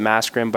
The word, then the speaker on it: Mascherin: I have an Italian background, and I think that's where sort of it gets confusing because the way they would pronounce it over there is different than obviously the way we pronounce it here. But yeah, I tell people to pronounce it Mascherin, but I can see why you would make that.